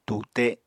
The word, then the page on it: tute: apatani